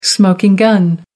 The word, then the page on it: smoking gun: American English